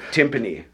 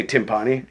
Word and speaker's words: timpani: Well, your pronunciation of timpani was poor. – Timpani. Yeah. Oh, did I say timpani? – Yeah, you did. No, it's a timpani.